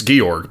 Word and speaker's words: Georg: And I think it's Georg, but... – There's no E at the end, so...